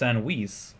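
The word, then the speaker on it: Sandweiss: We also have Ellen, and I believe it's pronounced Sanwis, like sandwich in Spanish.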